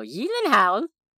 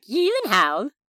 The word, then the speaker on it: Gyllenhaal: I'm just going to keep going off that one thing he said on that one late-night show and go, Gyllenhaal? That is how I'm going to pronounce it from now on.